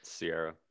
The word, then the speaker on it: Ciara: Ciara.